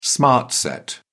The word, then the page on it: smart set: British English